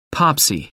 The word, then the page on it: popsy: American English